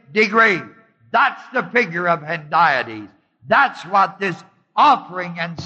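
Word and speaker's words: hendiadys: The figure of speech is called Hendiades. It's spelled H-A-H-E-N-D-I-A-D-Y-S.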